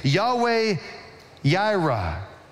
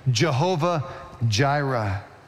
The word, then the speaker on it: Yahweh Yireh: And therefore, in verse 14, Abraham names the place Yahweh Yireh, or if you prefer the more traditional pronunciation, Jehovah Jireh.